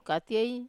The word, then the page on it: katiei: wamesa